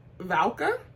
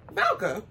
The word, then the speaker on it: Valka: Valka? Yeah, Valka by Terrible yes, Games.